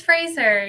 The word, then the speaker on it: Frazer: Did I say your last name right? – It's Frazer, yeah. – Frazer, not sure. Mm-hmm. Okay, cool. – Like blazer, yeah.